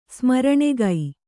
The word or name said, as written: ಸ್ಮರಣೆಗೈ